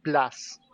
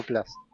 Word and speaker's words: Plus: Qué alegría me da oír como ustedes pronuncian Disney Plus con la U, bravo, como debe de, hacer todo castellano parlante. Aquí en España muchos se empeñan en decir Plus. Yo digo Plus.